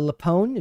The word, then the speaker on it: LuPone: Did you get a chance to watch the video of Patti uh, LuPone? Is that how you pronounce that name? – Yes. Uh, doubles down after F word mask tirade in Broadway backs her up.